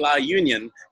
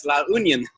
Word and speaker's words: La Union: We'll be heading to The proper pronunciation is La Union. I keep saying La Union, but it's La Union.